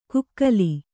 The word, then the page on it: ಕುಕ್ಕಲಿ: kukkali